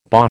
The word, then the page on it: bah: American English